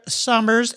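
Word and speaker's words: Sommer: You can find all these links on Kendra Summers. And I'm going to spell her name, I should say. I pronounce it Summer, but it's S-O-M-M-E-R.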